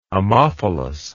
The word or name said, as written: ammophilous